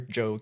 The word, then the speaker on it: Djo: It's called it's spelled DJO, but it's just pronounced Joe.